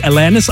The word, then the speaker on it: Alanis: Die Alanis aus dem Schwarzwald. Na gut, technisch gesehen spricht man sie Alanis aus.